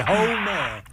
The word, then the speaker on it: Homir: H-O-M-I-R. Hein. C'est pas Homer Simpson, c'est Homer.